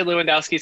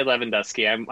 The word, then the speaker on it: Lewandoski: It's a very Polish name, so it's actually... Well, some people say Lewandowski, some people say Lewandowski.